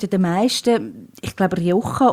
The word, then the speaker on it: Rioja: Und das andere wäre Rioja. – Rioja, also ist nicht so daneben gewesen.